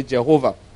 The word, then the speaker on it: Jehovah: But they did not know me as, depending on the pronunciation you want to use, the same thing. Some will say Yahweh, some will say Jehovah.